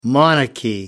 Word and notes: monarchy: American English

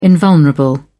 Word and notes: invulnerable: British English

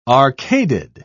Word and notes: arcaded: British English

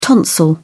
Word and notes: tonsil: British English

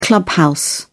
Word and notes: clubhouse: British English

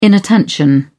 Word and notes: inattention: British English

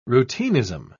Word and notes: routinism: British English

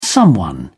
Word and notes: someone: British English

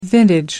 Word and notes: vintage: American English

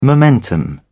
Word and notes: momentum: British English

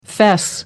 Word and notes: fess up: American English

